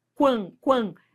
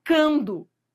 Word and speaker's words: quando: Esse u é pronunciado? Quan, quan. É. Né? Ele é pronunciado, você não fala cando.